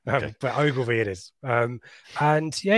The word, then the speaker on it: Ogilvie: Yeah, it was it was Sean Williams who went off, and it was Connor Ogilvie. I was enjoying your pronunciation there. – What did I say? Og- Ogilvar or something.